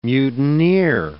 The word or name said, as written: mutineer